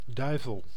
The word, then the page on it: diable: France (Paris); [lø djabl]